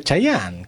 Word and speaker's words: Chayanne: No, es Cheyenne. – Cheyenne, ¿cómo lo pronuncia? Chayanne. ¿Cómo Cheyenne? – Sí. – Chayanne. Bueno. ¿Pero Cheyenne? ¿Le dicen Cheyenne? – No, Chayanne.